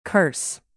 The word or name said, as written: curse